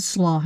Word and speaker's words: Slough: It's spelled S-L-O-U-G-H, but pronounced slough, close to slow.